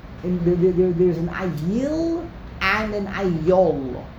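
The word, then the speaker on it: ayil (אַיִל): and the only difference between the two is the pronunciation. An ayol is a chaya, a wild animal. An ayil is a ram. It's a domesticated animal.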